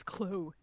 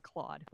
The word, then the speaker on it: Claude Giroux: G-I-R-O-U-X. And it's Claude Giroux. I'm sorry, I said Claude, like an American. – Americans are the worst. – Yep. Claude.